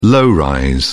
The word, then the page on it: low rise: British English